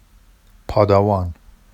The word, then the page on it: pɑdɑwɑn: Vafsi